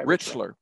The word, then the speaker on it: Richler: I'm never sure how to pronounce the novelist's name, Richler or Rickler? – Mordecai Richler.